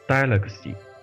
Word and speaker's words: Deluxe: 其实就是说这些日本人啊，非常喜欢搞这些谐音的，就比如说 DX，他们一定要念成 Deluxe，就是 D E L U X E，就是豪华版的意思。